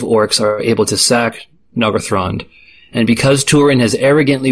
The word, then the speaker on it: Nargothrond: At this point in his misadventures, Túrin has been staying in the underground elf kingdom of Nargothrond.